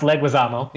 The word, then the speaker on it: Leguizamo: Well, his name is actually pronounced Leguizamo, yeah.